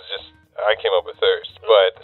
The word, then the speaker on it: Thrst: T-H-R-S-T. Yeah, it's kind of cool the way it's spelled. I get a lot of people saying first coffee. And after the third time of correcting, I'm just like, yeah, first.